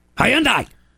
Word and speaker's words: Hyundai: Hyundai!